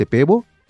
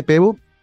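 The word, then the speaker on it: Pääbo: Eh, me refiero, por supuesto, al ganador del premio Nobel de Medicina que fue anunciado el día de ayer, el sueco Svante Pebo. Se escribe P-A-A-B-O, las dos A con cremillas arriba. Sí. Pebo, eh, que nació el 55.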